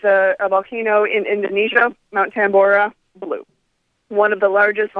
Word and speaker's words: Tambora: am I saying this right, Tambora? – Oh, yeah.